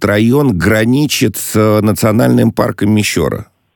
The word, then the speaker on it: Мещера: Да, интернет-энциклопедия все-таки дает Мещера именно на ее, ну и, соответственно, я делаю, да, ударение на этот слог. Хорошо, да, спасибо, принимается, так сказать. Буду Клепиковский район, Мещера, так, по крайней мере, говорят местные жители.